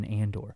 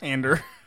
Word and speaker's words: Andor: His name was just Cassa, and then he changed it to Cassian Andor. Andor.